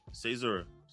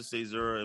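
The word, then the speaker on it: caesura: No, you just did it. Cezura. You pronounce it Cezura, and basically what it is, for those who don't know, it's like a break or a pause, especially in like music.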